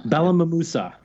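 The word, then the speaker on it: Balmamusa: what's the town? Balamosa? – Balamamusa. Yeah. – Ah. – Yeah. You'd be forgiven for missing one of the 19 syllables in that name.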